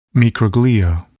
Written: microglia

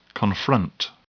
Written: confront

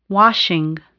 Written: washing

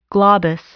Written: globus

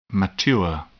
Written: mature

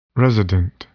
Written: resident

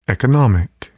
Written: economic

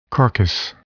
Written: carcass

